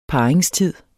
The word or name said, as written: parringstid